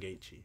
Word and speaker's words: Gaethje: Gaethje, is that how you pronounce it? – Justin Gaethje. – Gaethje, got it.